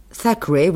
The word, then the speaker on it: Thackeray: Oui, vous prononcez bien. En effet, le TH, ça, Thackeray, oui, c'est cela.